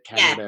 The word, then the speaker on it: Canberra: I don't know if that's how you say it, Canberra. – Yeah, it is, yeah.